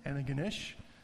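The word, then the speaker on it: Antigonish: And then we had friends in Anaganish, that's how you pronounce it, Anaganish, in Nova Scotia, and visited with them there from Peace River.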